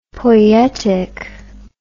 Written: poietic

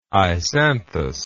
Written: isanthous

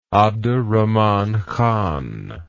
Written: abderrahman khan

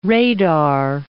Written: radar

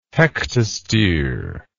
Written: hektostere

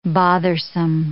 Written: bothersome

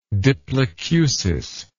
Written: diplacusis

